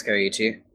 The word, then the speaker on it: Koichi: Well, why don't we start with the main character, Koichi. Am I saying that right? – Yeah, I think it's Koichi.